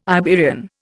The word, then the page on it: Iberian: Indian